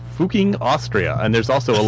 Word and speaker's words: Fucking: And the town is, of course, Fuching, Austria, and it's pronounced, or it's spelled in the English way, F-U-C-K-I-N-G. But it's actually pronounced Fuching.